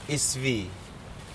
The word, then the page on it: isvii: Aneityum